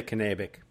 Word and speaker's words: Kennebec: Did I pronounce that right even? – We say Kennebec. – Serious? – Serious. – Really? – Yeah. – And I lived up there, but I always thought it was Kennebec. – There have been several articles in our paper over the years that tell us how to pronounce it. So.